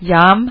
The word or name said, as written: džem